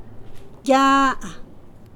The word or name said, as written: Quialana